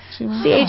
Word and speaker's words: Chima: Is that how you say your last name? – Chima.